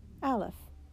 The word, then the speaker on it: Aleph: "This printed letter we pronounce Aleph," he said.